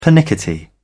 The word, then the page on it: pernickety: British English